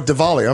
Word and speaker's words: Diwali: You pronounce the W like a V. – Oh, Diwali, I'm sorry.